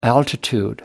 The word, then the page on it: altitude: American English